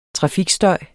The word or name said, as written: trafikstøj